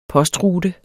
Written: postrute

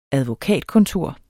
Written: advokatkontor

